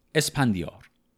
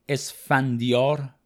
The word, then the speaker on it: اسپندیار: املا و تلفظ این نام در تقریبا تمام متون شاهنامه‌ای که ما داریم اسفندیار با ف نوشته شده اما آقای دکتر خالقی مطلق در تصحیح متأخر خودشون عوضش کردن و اسپندیار ذکر